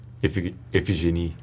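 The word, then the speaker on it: Iphigénie: Der zweite Gast heute singt üblicherweise in Opern wie Othello und der Rosenkavalier mit und probt derzeit für die Gluckoper Iphigene en Tauride, wenn ich das jetzt richtig ausgesprochen habe. Iphigen? – Iphigenie. – Iphigenie, okay.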